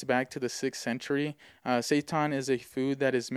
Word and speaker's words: seitan: Now, something I recently found out about is something called seitan. It's spelled S-E-I-T-A-N. I know it sounds like you're saying the devil Satan, S-A-T-A-N.